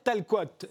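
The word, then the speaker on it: Tal-Coat: Vous venez d'ouvrir un nouvel espace permanent dédié au peintre Pierre Talcoat. Est-ce que je l'ai bien prononcé d'ailleurs C'est tout à fait ça. C'est du breton.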